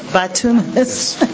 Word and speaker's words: bituminous: Bituminous.